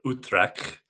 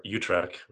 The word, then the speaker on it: Utrecht: So I spent the first year in Amsterdam, and then about 18 months ago, bought a house in Utrecht, or Utrecht, which is the easier American pronunciation, which is like maybe 30 minutes south of Amsterdam.